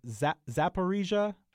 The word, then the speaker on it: Zaporizhzhia: I will spell it for you. Z-A-P-O-R-I-Z-H-Z-H-I-A. – Yeah, now see, that's hard. And if you just read it, it would be extra hard. Now, I hear it pronounced fairly often on, again, NPR, so that helps. I'm not still 100% sure, especially on the P part, but I usually hear it as Zaporizhia. – Yes.